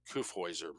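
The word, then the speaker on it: Kyffhäuser: K-Y-F-F-H-A-U-S-E-R with an umlaut over the U. So A-U with an umlaut over the U is pronounced oi. Kiffhäuser, more or less.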